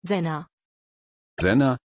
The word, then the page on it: Zena: German